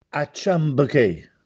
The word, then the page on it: Acciampechè: Castellanetano